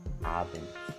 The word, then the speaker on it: Abend: Guten Abend. So the D is not pronounced like D. Rather, it's pronounced like T.